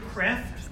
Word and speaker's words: Kreeft: A little bit older is a book by Peter Kreft, though it's spelled Kreeft, K-R-E-E-F-T, called Back to Virtue.